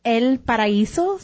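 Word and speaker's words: El Paraíso: It's El Paraiso, so the I, you would pronounce the E, so kind of Paraiso.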